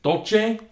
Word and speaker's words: Agua Dulce: Dulce? – Dulce. Yeah, you said it right, man.